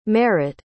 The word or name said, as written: marot